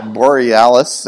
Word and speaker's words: borealis: How do you say it? However you say that? Yeah, borealis. Thank you.